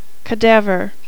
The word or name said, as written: cadaver